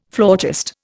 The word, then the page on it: flautist: Indian